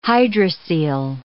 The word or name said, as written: hydrocele